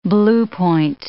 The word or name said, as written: bluepoint